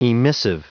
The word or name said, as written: emissive